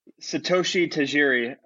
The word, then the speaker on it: Satoshi Tajiri: His name is Satoshi Tajiri. And he's a creator. – Okay, Tajiri.